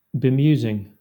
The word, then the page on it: bemusing: Southern England; /bɪˈmjuːzɪŋ/